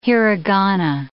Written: hiragana

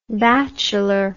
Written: batchelor

